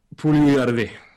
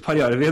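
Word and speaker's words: Puljujärvi: Uh, eh, Jesse Poljujärvi. – Ja. – Som Altså, det blir jo ve veldig litt Parjärvi når amerikaneren uttaler det.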